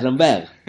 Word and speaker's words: Lambert: Me enteré recientemente que se pronuncia Christopher Lambert. – Ah, es Lambert.